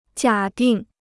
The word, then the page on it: 假定: jiǎ dìng